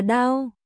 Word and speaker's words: đao: Chữ này đọc là đao.